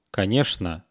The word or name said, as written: konechno